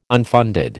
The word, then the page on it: unfunded: American English